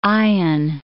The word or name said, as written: eyen